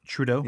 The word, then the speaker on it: Trudeau: Trudeau? – Trudeau.